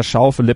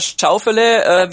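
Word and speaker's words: Schauffele: Und das Interessante ist aber, wie die amerikanischen Kollegen den Namen aussprechen. Da ist er nämlich Xander Schaufele. – Schaufele. – Also das ist schon eine sehr, sehr große Verballhornung.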